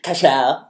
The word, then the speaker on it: Cashel: Cashel.